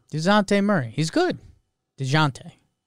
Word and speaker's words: Dejounte: But so Paul said, I learned to pronounce DeJounte's name by saying DeJounte fast. You get a sort of Z sound at the start of the second syllable. DeJounte.